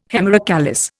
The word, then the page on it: hemerocallis: Indian